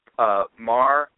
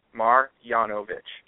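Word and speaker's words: Marjanović: The pronunciation is Marjanovic, like yawning, Marjanovic. That is the pronunciation, Marjanovic. So, okay.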